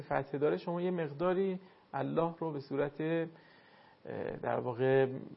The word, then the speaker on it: الله: زمانی که کلمه الله اگر قبلش فتحه یا ضمه باشه، در این صورت الله لامش درست تلفظ میشه، مثلا هو الله، درست شد؟ – بله.